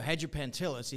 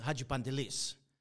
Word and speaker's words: Hagipantelis: So Hagipantelis is Hagipantelis.